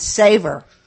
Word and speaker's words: Sever: I didn't realize that although it's spelled S-E-V-E-R, it's actually pronounced Savor.